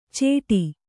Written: ಚೇಟಿ

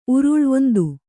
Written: ಉರುಲು

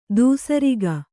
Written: ದಾಸೇರಕ

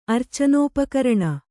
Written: ಅರ್ಚನೋಪಕರಣ

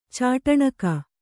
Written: ಚಾಟಣಕ

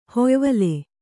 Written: ಹೊಯ್ವಲೆ

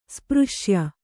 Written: ಸ್ಪೃಶ್ಯ

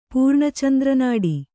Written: ಪೂರ್ಣಚಂದ್ರನಾಡಿ

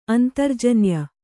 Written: ಅಂತರ್ಜನ್ಯ

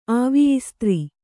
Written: ಆವಿಯಿಸ್ತ್ರಿ

